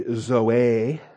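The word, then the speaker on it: Zoe (ζωή): It's the long O and the long E, though, so you pronounce the omega uh, as an O and the eta as an A, and it should really be Zoe, but we don't call my daughter that.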